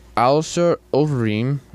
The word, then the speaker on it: Alistair Overeem: Alistair Overeem, I think I said it right. – That's right.